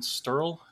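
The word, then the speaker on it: Sterle: He sent me this video post on Facebook back in late September by one of your senators, Glenn Sterl is his name. Is that how you pronounce it? – That's right.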